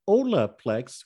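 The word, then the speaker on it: Holaplex: Yeah, so it's H-O-L-A-P-L-E-X, pronounced Olaplex.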